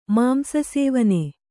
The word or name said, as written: ಮಾಂಸಸೇವನೆ